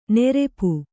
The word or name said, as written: ನೆರೆಪು